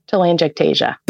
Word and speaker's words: telangiectasia: Hereditary hemorrhagic telangiectasia.